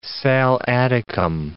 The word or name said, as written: sal Atticum